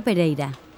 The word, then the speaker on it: Pereyra: Sí, me llamo Claudia Pereira. – ¿Cómo se escribe su apellido? – P-E-R-E-Y-R-A.